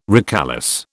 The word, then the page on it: recalesce: British English